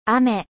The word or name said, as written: 雨